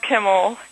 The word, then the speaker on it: Kimmel: Well, it is pronounced Kimmel, K-I-M-M-E-L.